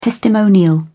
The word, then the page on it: testimonial: British English